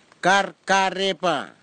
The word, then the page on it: karkarepa: kwamera; Tanna